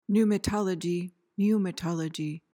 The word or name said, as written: pneumatology